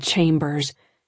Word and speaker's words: Chambers: He pronounced it the Southern way, as though it ended in a Z. – Ms. Chambers.